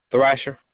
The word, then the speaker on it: Thrasher: Thrasher.